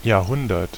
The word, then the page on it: century: [ˈsɛn.tʃə.ɹi]; /ˈsɛn.tʃə.ɹiː/; /ˈsɛn.tʃɚ.i/